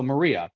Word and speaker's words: Marija: Like, one of our coworkers' name is spelled M-A-R-I-A, right? So Maria, but it's not. That's how you say it in Serbian. It's Maria. – M-A-R-I-J-A, yeah. M-A-R-I-J-A.